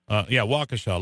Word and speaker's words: Waukesha: Waukesha, lovely town.